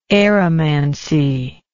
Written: aeromantic

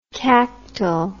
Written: cactal

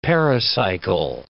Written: pericycle

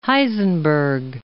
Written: Heisenberg

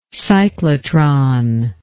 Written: cyclotron

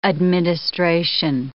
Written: administration

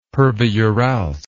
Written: pervouralsk

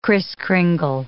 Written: Kriss Kringle

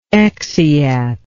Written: exeat

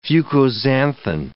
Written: fucoxanthin

